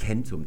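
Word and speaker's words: centum: Das D fällt natürlich raus, das K, das ist dieses hier, das wird zu K und es wird eben im Lateinischen C geschrieben. Das M wird zu N, weil das T folgt und im Lateinischen wird dann, wenn das M im Kern der Silbe steht oder das N, ein E eingefügt und da hinten sehen wir dann die normale Fortführung der Endung. Das ist also Kentum, das Wort für 100 im Lateinischen und deswegen heißt es Satim und Kentum.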